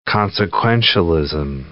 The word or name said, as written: consequentialism